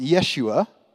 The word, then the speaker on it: Yeshua: And likewise, when we first hear the name Jesus, or how it's actually pronounced, Yeshua, we can understand a little bit about who Jesus is.